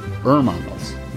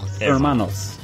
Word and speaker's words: Hermanos: You do not pronounce the H in Spanish. Hermanos, right? Anyway. – Yeah. – Hermanos.